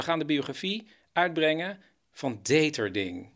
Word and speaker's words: Deterding: Hoe zou je dit uitspreken? Deterding. – Deterding. Een Nederlandse meneer, die heette Deterding.